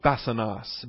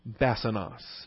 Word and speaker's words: basanos: Vocabulary for torment is called basanos, B-A-S-A-N-O-S. Accent on the first syllable. Basanos, B-A-S-A-N-O-S, basanos.